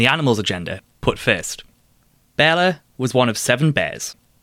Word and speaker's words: Barle: But what we do know is what happened next in Bearla's story. That's spelt B-A-R-L-E and pronounced Bearla.